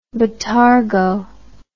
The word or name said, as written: botargo